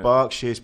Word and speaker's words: Berkshire: So we got Buckinghamshire, Hertfordshire, Essex, Berkshire. Is that how you say it? – Berkshire.